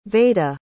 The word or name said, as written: vaeda